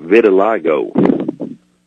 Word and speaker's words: vitiligo: I'd like to talk to you a minute about vitiligo.